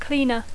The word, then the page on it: cleaner: English